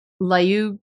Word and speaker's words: Lyubomirsky: Oh, I always say her name wrong. Lyubomirsky. It's like Lyubomirsky.